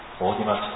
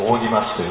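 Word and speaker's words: 正親町: しかしもう一つはですね、ある歴史家が指摘するのはですね、天皇、これはまあこれもデリケートなことなんですけども、ある少なくとも 16 世紀の 1565 年ですね、キリスト教を廃止してからのことなんですけども、大木町天皇、大木町って正しい親の町と書いて、正しい親の町と書いて大木町という通り、大木町天皇が大薄払い、大きな薄払いですね、払いのことですよね。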